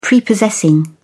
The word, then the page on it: prepossessing: British English